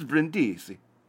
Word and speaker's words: Brindisi: Is that right? Is that how you say it in Italian? Brindisi. I'm trying to get the accent right. And Frankfurt. Yeah, it's not Frankfurt. It's Frankfurt. It's Brindisi. Yeah.